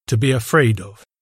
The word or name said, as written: be afraid